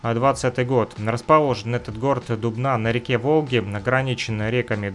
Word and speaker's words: Дубна: Вот, любит граффити, да, я сказал Дубна, Дубна называется этот город на самом деле, чтобы вы вот правильно слышали и называли ударение, а то я вот с ошибками иногда говорю.